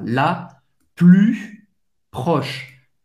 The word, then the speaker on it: la plus proche: A mais próxima, la plus proche. U, de novo, faz a boca do O e fala I, U, la plus proche. Você não vai pronunciar o S no final.